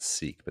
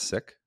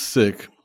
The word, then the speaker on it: Sikh: I wonder, from your experience, I always pronounce it seek, but that's not correct. It's pronounced sick. Is that correct? So it's pronounced sick.